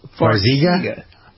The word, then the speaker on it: Farxiga: Is that how you pronounce it? Farxiga? Farxiga? Maybe that's how you pronounce it. I don't know. – Now you're close. – Farxiga? – Yes, is how it's pronounced.